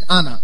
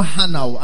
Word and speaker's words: honour: I pronounce it Anna. Or the other people say honor. But it is Anna. H-O-N-O-U-R. Anna. The Americans spell it as H-O-N-O-R. Whichever way, we are learning about Anna today. Say Anna. It's not Hannah as in someone's name. Anna.